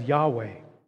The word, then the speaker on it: Yahweh: which then became pronounced as Yahweh.